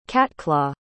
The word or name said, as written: catclaw